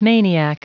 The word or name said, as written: maniac